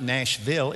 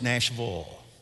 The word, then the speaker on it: Nashville: And by the way, natives know it's not Nashville, it's Nashville. We know that. Everybody ought to know that.